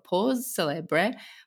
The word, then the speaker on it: Pause Celeb: It's Pause Celeb. – Celeb. Okay, without the R-E at the end.